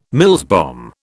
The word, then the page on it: Mills bomb: British English